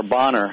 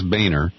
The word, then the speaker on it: Boehner: Well, Peter, you know, John Boehner, or Bonner, however you pronounce his name. – He actually says Boehner.